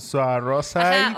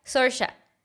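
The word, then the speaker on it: Saoirse: Sí, o sea, deletreado es como Suarosa. – rosa o sea, se lo deletreó Ellen y dijo, sí, pero así no me llamo mi nombre se pronuncia Sorsha.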